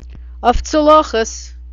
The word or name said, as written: oiftsalochis